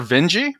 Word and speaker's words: Vinge: But I've only actually read now two of his books, and it's Werner Vinge. Rhymes with Benji.